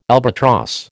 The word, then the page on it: albatross: American English